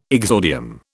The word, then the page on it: exordium: British English